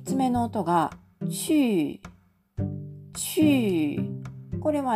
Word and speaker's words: 去: この漢字が当たるんですが、発音記号は QU と書いて、第 4 世、上から下に急降下する音になります。これはあの、チューというふうに、発音、えっと、母音がですね、うの母音みたいに見えるんですが、